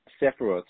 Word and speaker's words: Sephiroth: And a lot of these attunements are around the Sephiroth.